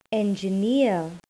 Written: engineer

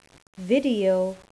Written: video

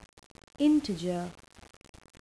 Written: integer

